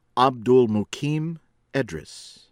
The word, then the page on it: Abdulmukim Edris: American English